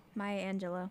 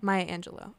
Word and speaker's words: Maya Angelou: So I have the exact pronunciation. – Okay. – Oh, hold on. – Maya Angelou. – Maya Angelou.